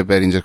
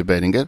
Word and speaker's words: Behringer: per favore favore si legge Beringer con la G dolce, non si legge Beringer.